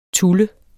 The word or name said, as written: TULLENDE